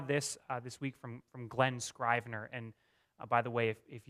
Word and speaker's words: Scrivener: It is Scrivener, not Scribner, which we said last week.